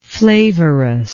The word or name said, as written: flavorous